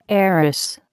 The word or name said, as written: heiress